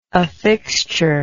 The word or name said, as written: affixture